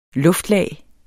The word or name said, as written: luftlag